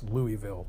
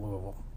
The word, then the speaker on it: Louisville: But anyway, the Louisville thing is, or Louisville, as I should say, is similar. So I lived here, but I'm not from here originally, so it's Louisville to me, not Louisville.